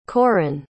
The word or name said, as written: koren